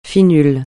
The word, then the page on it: FINUL: francais